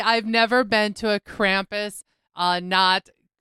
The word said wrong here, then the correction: Krampusnacht: celebration. – Krampusnacht. – All right, you said it correctly.